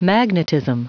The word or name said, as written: magnetism